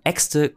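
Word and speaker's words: Exte: Nicht wie die deutschen Äxte, die der Plural von Axt, sondern Äxte, Kurzform für Extensions.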